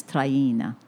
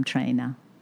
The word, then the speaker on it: Traina: If you were in Italy, my last name is Traina. But here in the United States, I'm trainer. – Right. – And a lot of people always thought that I just don't know how to say my name. They think I'm using my Bronx accent when I say trainer.